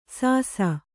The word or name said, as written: ಸಾಸ